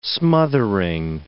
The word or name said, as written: smothering